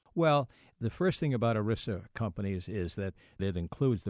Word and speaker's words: ERISA: And this is self-insured companies known as ERISA companies.